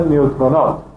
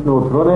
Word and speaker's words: نوترون: نیوترونات هسته مرکب است از پروتون و نوترون، تلفظ فرانسویش نوترونه، انگلیسیش نیوترون، اون اوش در فرانسوی در انگلیسی یو تلفظ میشه میشه نیوترون.